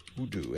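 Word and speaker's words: UDOO: It's U-D-O-O. – Udu.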